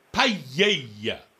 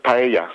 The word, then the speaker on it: paella: It's paella. So double L in Spanish is pronounced Y. So just think of it that way.